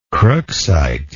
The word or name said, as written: crookesite